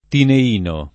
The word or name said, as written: tineino